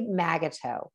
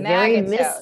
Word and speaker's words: Magato: It is pronounced a little differently than it's spelled. Betsy Magato. It's a Magato. Misspelled French name a couple of times, thanks to thanks to immigration and thanks to the armed services of the United States.